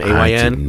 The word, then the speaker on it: Ayn: Ayn. That's how it's pronounced.